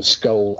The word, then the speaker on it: Scole: And people can, you know, are probably better served by looking it up for themselves if they just Google Skoll, S-C-O-L-E.